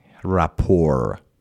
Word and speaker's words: rapport: به این کلمه بچه ها، rapport، A-R-A-P-P-O-R-T به چه معنی هستش؟ تی آخر اول از هم بگم تلفظ نکنید، rapport خونده میشه، استرس هم یا فشار هم روی پی هست.